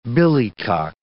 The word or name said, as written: billycock